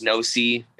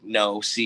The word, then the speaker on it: Knowsee: The name is Knowsee. It's spelt how it sounds, like Knowsee, you know, Knowsee.